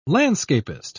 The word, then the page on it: landscapist: British English